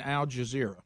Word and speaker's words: Al Jazeera: There's no T in Al Jazeera. – I like when he says heard it pronounced that yeah. – way. – Yeah. I think I'm hearing the J as a T.